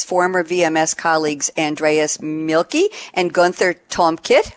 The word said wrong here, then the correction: Tolkmit: I hope I pronounced that right, Tolkmit, they formed TRUFA, short for True Finance Applications.